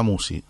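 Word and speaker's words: Musci: Y después se ve en el canal que lleva mi nombre, Marisa Musi.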